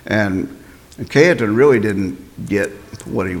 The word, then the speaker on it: Cajetan: He first debated a cardinal, Cardinal Cajetan, it's spelled Cajetan, but it's Cajetan, and he was steeped in Roman Catholic tradition, and he was simply citing papacy, papal decrees, and councils.